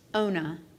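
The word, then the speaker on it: Yonah: Actually, it's correctly pronounced Ona. But we pronounce the J and it's Jonah. We pronounce it Jonah, but it's actually Ona.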